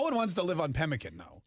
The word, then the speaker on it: Pemmican: Pemmican. I don't know how you say it. – Pemmican.